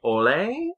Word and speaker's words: Hole: And he's playing Detective Harry Ole, I believe is our listen, it's spelled H-O-L-E, Detective Harry Hole.